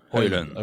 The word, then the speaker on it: Højlund: Hoyland.